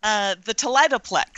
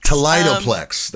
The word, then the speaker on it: Toledoplex: There's a project that is referenced on your website that I'm deeply curious about, and I'm going to mispronounce it, I'm sure. Toledoplex? – The Toledoplex. – Toledoplex. That sounds much better.